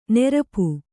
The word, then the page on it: ನೆರಪು: nerapu; kannada